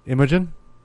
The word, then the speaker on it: Imogen: And it's got our boy Jesse Serra, Imogen Poots. And that's about it. There's not much. – Yeah, how do you pronounce her first name? – Imogen. – Imogen. – It's like, yeah, Imogen.